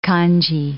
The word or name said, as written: kanji